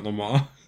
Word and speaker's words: Le Mans: I don't think I'm saying that right. I think it's Le Mans. – It is 100% Le Mans. – It is Le Mans.